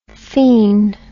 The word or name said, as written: phen